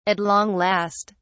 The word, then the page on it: at long last: British English